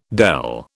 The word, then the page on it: dele: British English